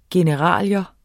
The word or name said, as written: generalia